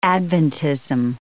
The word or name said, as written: Adventism